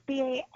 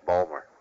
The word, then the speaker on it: Baltimore: If you're from down around Balmer, it's pronounced Balmer. – Yeah. And then how do you pronounce it? – Baltimore. – You have the T in it. Okay. – Yeah, we say all the consonants.